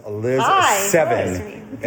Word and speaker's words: Lizz Sevin: This is Lizz Sevin. – And good pronunciation. – Thank you. Well, I thought of the number.